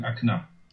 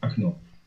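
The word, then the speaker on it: Akna (окно): Okno ist das Fenster. Die Polen sagen Okno, die Russen sagen Akna. – Ah. – oder Akno, so, Akno. Vorne das O wird wie A ausgesprochen.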